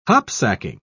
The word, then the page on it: hopsacking: British English